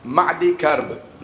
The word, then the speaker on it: معدی کرب: تو مرہ بن قیس کے بڑے بڑے پہلوان, ایک معدی کرب نام, مہدی نہیں, معدی کرب نامی بہت بڑا پہلوان تھا. میم عین میم ہے سے نہیں, باقی ہجے وہی ہیں.